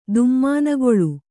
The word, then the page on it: ದುಮ್ಮಾನಗೊಳು: dummānagoḷu